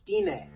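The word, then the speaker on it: Stine: Her name's pronounced Stina.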